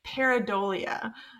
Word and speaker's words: pareidolia: is gonna be pareidolia.